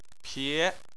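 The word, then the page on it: pie: tone 2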